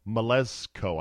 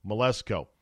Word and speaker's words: Mleczko: Melezko, I believe is how you pronounce her last name, M-L-E-C-Z-K-O, Melezko.